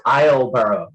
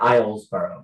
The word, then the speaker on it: Islesboro: It's pronounced Isleboro, not Islesboro. – That's how the locals say Isleboro.